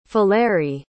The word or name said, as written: faleiry